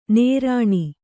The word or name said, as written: ನೀರುಣ್